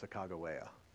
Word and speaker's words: Sacagawea: So when Lewis wrote his journal in 1804 and 1805, he wrote everything phonetically. So it's actually pronounced Sacagawea.